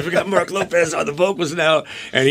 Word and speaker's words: Lopes: But you guys, you know, on an uplifting note, you guys have a new awesome vocalist, Mark Lopez. – It's actually pronounced Lopes, but we're going to go with Lopes.